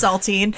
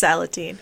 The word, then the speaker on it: Salatine: Worth pointing out that the actor who played Thomas Edison was in The Case of Androzani, the Fifth Doctor's final story, as a character called Salatine, or I don't know if there's another way to pronounce that. – Saltine. – Yeah, Saltine. Saltine the cracker. – I'm pretty sure it's Salatine. – Salatine.